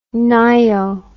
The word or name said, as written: ngaio